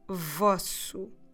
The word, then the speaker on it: vosso: We need the word vosso. Vosso.